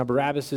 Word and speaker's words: Barabbas: Now, Barabbas is the English pronunciation of this. Uh, and how they said it, I'm not exactly sure. Baraba, probably. But.